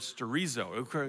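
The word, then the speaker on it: sterizo (στηρίζω): Now, that's how we say it in English, but in the Greek text here, it's the word sterizo. It comes from that word, kind of like chorizo, but it has nothing to do with burritos. It just rhymes with it.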